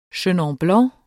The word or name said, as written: chenin blanc